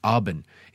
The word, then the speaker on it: Auburn: Auburn. It's A-U-B-U-R-N, but if you'd like to pronounce it like the people around here pronounce it, you would pronounce it A-W-B-I-N.